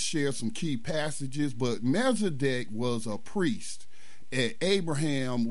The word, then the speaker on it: Melchizedek: But Hebrews 7 talks about, how do you pronounce his name? Melchizedek, the priest. – Melchizedek, yeah.